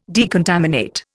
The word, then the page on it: decontaminate: Indian